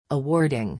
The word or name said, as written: awarding